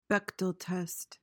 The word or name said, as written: Bechdel test